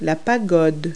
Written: pagoda

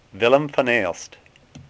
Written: Aelst

